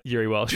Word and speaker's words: Jiri Welsch: Shout out Jerry Welsh. – yeah, yeah. Uh, is that a silent J, is it? Sorry, sorry, I, I, uh, Jiri Welsch.